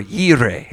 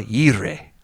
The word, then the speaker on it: Jireh: Jehovah Jireh, or Yireh is actually how it's pronounced. Brother Keith Moore corrected me on that once. Jehovah Yireh.